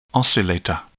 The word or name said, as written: oscillator